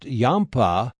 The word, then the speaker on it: Yampa: Yirpah is a Shoshone word generally pronounced Yampa, from which Gairdner's Yampa, Paradiridia gairdneri, takes its name.